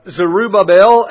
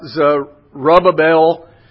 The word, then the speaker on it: Zerubbabel: both of these names actually have four syllables in them. So when I think about Zerubbabel, I think about rub-a-dub-dub, three men in a tub.